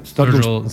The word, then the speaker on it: Sturgill: So how do you say his name? Sturgo? How do you say it? Sturgill Simpson. Yeah.